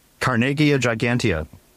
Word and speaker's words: Carnegiea gigantea: Carnegiea gigantea.